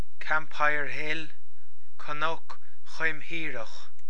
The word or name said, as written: Cnoc Chaimthíreach